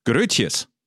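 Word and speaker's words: Groetjes: Also kein zusätzliches E gesprochen am Ende, keine Betonung, als würde einem ein halber Liter Sabber das Kinn runterlaufen. Keine Ursache, schreibe und zum Schluss Grötjes.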